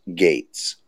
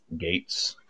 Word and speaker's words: Gaetz: Uh, Mike says that it's pronounced Gates. Gates. Yeah. – It's like it's spelled so weird. I get it wrong because it's it's like G A E T Z, something like that.